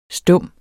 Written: stum

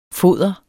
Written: foder

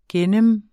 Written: gennem

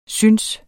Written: synes

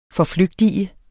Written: FORFLYGTIG

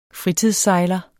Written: fritidssejler

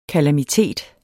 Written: KALAMITETS

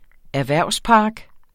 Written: erhvervspark

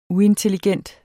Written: uintelligent

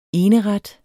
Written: eneret

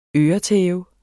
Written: øretæve

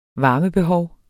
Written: varmebehov

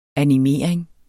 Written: animering

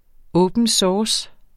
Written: open source

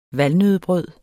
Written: valnøddebrød

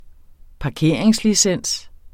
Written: parkeringslicens

